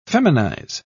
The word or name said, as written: feminize